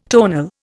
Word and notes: tonal: Indian

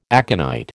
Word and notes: aconite: American English

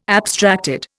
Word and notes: abstracted: Indian

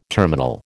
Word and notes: terminal: American English

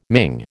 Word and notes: Ming: American English

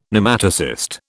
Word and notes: Nematocyst: British English

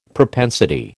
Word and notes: propensity: American English

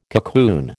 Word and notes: cocoon: American English

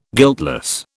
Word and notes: guiltless: British English

